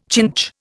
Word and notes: chinch: Indian

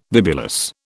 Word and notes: bibulous: British English

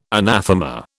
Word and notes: anathema: British English

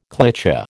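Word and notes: cliché: American English

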